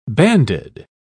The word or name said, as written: banded